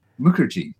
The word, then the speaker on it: Mukherjee: And well, folks, the author is Abir Mukherjee. Did I say that right? – Mukherjee. No, the English have mispronounced it for 100 years.